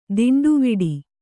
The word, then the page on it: ತೊಂಡುವಾಡೆ: toṇḍu vāḍe